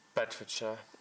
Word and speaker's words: Bedfordshire: Bedfordshire.